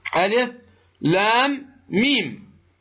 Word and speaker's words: الم: سورة البقرة، ألف لام ميم، هذه اللام نهايتها ميم عند النطق بها، تقول لام، جاء بعدها ميم، فتدغم الميم الأولى في الثانية، تدغم الميم الأولى في الثانية بمقدار ماذا؟ وغن بمقدار حركتين، وهنا عندنا إدغام، فيكون هنا الإدغام ما به؟ مثقل، فتقول ألف لام ميم،